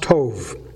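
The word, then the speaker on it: Tov (טוב): Well, it's T-O-W-B, but it's pronounced Tov, okay?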